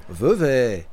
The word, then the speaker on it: Vevey: Enfin, Vevey, non, Vevey, quand même. Euh, Merci. – Tu découvres. Non, mais il faut faire attention à la prononciation locale.